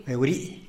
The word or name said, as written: beurii